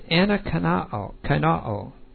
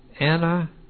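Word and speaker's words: anakaino (ἀνακαινόω): So the first verb is anakaino. Anakaino. And then this is a long O here. Anakaino.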